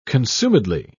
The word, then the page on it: consumedly: British English